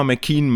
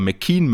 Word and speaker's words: McKeon: Nee, Kaylee McKeon, beide sehr ähnlich geschrieben, Emma McKean mit K-E-O-N und Kaylee McKeon, K-E-O-W-N, auch hier mal schön die wirklich native australische Aussprache zu hören, dann kriegt man auch mal mit, dass das, dass die Emma, die 100 Delfin-Schwimmerin, eher McKean mit Doppel-I quasi gesprochen wird und McKeon noch so ein kleines O mit drin hat.